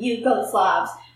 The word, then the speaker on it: Yugoslavs: I have different ways to end our chant, but it ends with Yugoslavs as three distinct syllables.